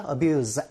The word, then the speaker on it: abuse: 이때는 동사이기 때문에 z 발음이 나와야 되겠죠. abuse, animals. 같이 알아주시면 좋겠습니다.